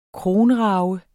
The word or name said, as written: kronrage